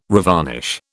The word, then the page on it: revarnish: British English